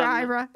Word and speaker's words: Aira: It's called Ira, and it's spelled with an A-I-R-A, yeah.